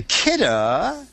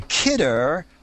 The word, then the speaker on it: Kidde: It is the Kidda, and I believe that's a pronunciation, even though it's spelled K-I-D-D-E, the Kidda Wireless Smoke Alarm System.